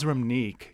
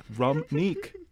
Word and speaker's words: Ramneek: My name is Ramneek. You blend the words rum and unique. Those are English words, Ramneek.